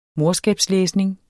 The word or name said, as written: morskabslæsning